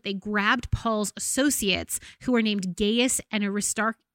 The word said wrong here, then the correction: Aristarchus: Uh, Aristarchus, guys, I'm bad at pronouncing these names.